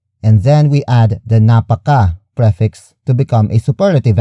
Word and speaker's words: napaka: The second way to turn Tagalog root words into superlative adjectives is by adding the prefix napaka. Napaka. Napaka. Napaka is spelled as N-A-P-A-K-A.